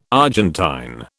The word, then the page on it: Argentine: British English